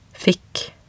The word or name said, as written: fikk